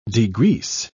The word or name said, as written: degrease